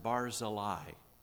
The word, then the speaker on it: Barzillai: We just read a few verses of a man named Barzillai. Some people pronounce it Barzillai, but it doesn't matter.